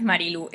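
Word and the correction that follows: Marie-Laure: es Marilor, sería algo así, Marilo Lour.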